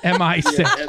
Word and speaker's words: MI6: You know, a spy film, you know, British M16. – MI6. – I always said it wrong.